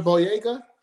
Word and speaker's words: Boyega: John Boyega? – Boyega, yeah.